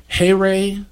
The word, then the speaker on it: chaire: What the actual greeting would have been Heire, and which is like spelled like chair, C-H-A-I-R-E, and that's the hail, right?